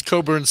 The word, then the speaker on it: Cockburn: Bruce Coburn spells it C-O-C-K-B-U-R-N, but don't pronounce that like the way it's written.